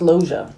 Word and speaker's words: Loggia: You got Robert Loggia. I don't know how you say that. – We had a death check. Oh, I forgot. – It's actually pronounced Loja. – Loja, okay.